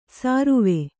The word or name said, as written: ಸಾರುವೆ